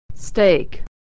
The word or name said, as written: steak